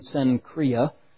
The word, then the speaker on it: Cenchrea: It says that she's a servant of the church in Cenchrea. That's how you pronounce that, at least the best way we can figure in English for that when you're reading it.